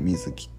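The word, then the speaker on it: 水城: そうなのでそこの面に関しては水木っていうあの水に城って書いて水木って読むんですけど、まあ、その水木っていうのを作るんですね。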